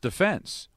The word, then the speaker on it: defense: It's pronounced defense. We now forever pronounce it defense because of the chants at Yankee Stadium back in the late 50s, and, of course, played in that, you know, greatest game ever played in 58, and just a tremendous individual.